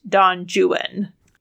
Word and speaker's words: Don Juan: and Byron used strict rhyme schemes in all of his work and constantly is rhyming the name of his protagonist with phrases like true one or the word ruin, which is how we know that he was very British and wanted the name pronounced Don Juan.